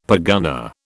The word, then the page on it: pergunnah: British English